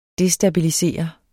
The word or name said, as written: destabilisere